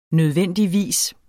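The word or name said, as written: nødvendigvis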